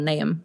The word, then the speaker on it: Niamh: Niamh is an Irish name, spelt N-I-A-M-H, and traditionally pronounced Niamh.